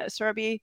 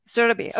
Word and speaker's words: Surabhi: If you guys want to find Surabhi, and did I say that right again? Please say it. – Yeah, Surabhi. – Say it right. Surabhi.